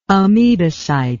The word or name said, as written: amebocyte